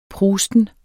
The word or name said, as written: prusten